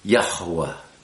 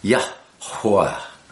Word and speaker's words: Yahuwah: And you'll hear me pronouncing that hey in the center with a little more emphasis. Yahuwah. Yahuwah. It's two syllables, really, Yah and Hua, and you blow the Hua so that there's almost a little syllable in front of the second syllable.